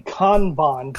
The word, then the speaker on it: Kanban: K-A-N-B-A-N methodologies. – Well, it's actually Kanban, but for most of us in America, we just call it Kanban. – Kanban. That sounds very Midwest. Kanban.